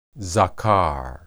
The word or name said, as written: zakar